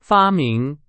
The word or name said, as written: 发明